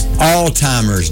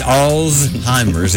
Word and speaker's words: Alzheimer's: Kept pronouncing Alzheimer's disease. It's Alzheimer's.